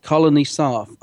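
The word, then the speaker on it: Colony Sarff: Actually, I was going to say, how do you say it, guys? Colonel Sarf? – Colony Sarf.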